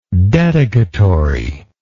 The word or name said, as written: dedicatorily